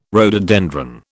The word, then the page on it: rhododendron: British English